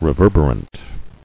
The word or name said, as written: Reverberant